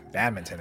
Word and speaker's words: badminton: No, I didn't know there was an N in there. – Yeah, badminton. That's how it's spelled.